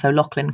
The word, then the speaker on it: O'Loughlin: Well, you, Jamie, I think are the first person I have met who's managed to pronounce O'Loughlin correctly, who does not have Irish, who is not, you know, coming from this island. – Okay. – One of the big issues is having a name that nobody can pronounce. Like, it's fine here, but literally in the UK, people get to the O and then they freeze because they don't know how the GH works.